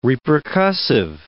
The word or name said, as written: repercussive